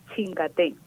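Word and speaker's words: Chingatpé: Acento en la E, Chingatpe, con tilde en la E.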